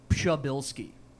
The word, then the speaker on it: Przybylski: All right, so the American version is Przybylski, but I've been trying to get in touch with my roots, and the Polish pronunciation is Przybylski. – Przybylski. – Yeah, with a little P to start it off, though.